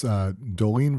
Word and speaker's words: Dolin: If you look for it on a shelf, it's spelled D-O-L-I-N, but it is pronounced Dolin.